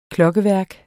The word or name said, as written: klokkeværk